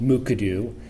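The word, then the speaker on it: Mukurtu: The Plateau People's Web Portal is based on software developed at Washington State University called Mukadu, and the R is silent. That trips up many people.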